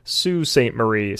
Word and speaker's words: Sault Ste. Marie: I am editing this podcast right now, and I have since realized that it is actually pronounced Sault Ste. Marie, so I'm going to mispronounce it throughout the entire podcast.